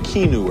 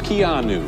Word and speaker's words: Keanu: Thank you for mispronouncing your name. I was saying Keanu or something. It's Keanu.